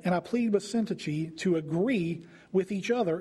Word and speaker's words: Syntyche: And according to the Bible pronunciation guide website that I looked this up on, their names were Euodia and Syntyche, because I had no idea how to say either one of those.